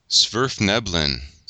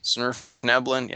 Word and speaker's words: Svirfneblin: Svirfneblin. – Svirfneblin, yeah, I'm terrible at the pronunciations.